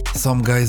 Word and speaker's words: Sumgii: Somgaj, bo tak naprawdę wymawia się jego pseudonim, to pochodzący z UK producent o niepowtarzalnym stylu.